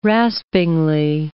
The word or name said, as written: raspingly